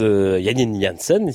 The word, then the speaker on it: Janine Jansen: On commence la semaine avec l'histoire de la violoniste néerlandaise Janine Jansen, si on le prononce justement à la hollandaise.